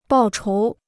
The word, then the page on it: 报仇: bào chóu